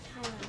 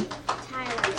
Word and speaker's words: Tyler: Tyler. – Who? – Tyler. – How you spell that? T-Y-L-E?